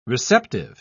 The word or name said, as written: receptive